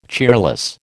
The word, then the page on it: cheerless: American English